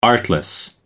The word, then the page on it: artless: American English